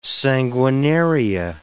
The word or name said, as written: sanguinaria